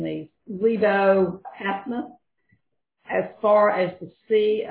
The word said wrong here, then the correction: Lebo Hamath: So it's Lebo Hamath and the Sea of Arabah.